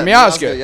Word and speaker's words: Miazga: Yeah, Miazga.